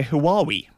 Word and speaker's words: Huawei: And that is made by Huawei, if that's the correct pronunciation. I think it is. – I believe it is.